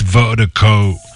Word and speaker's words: Virdiko: It's the global music promotion, and Vertigo is spelled V-I-R-D-I-K-O. That's V-I-R-D-I-K-O, pronounced Vertigo.